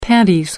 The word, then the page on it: panties: American English